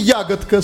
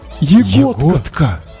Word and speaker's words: Ягодка: Депутат Андрей Ягодка с ударением на О. То есть он... – Ягодка.